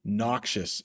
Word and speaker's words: noxious: Noxious is how you say that one.